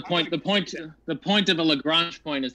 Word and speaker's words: Lagrange: Lagrange.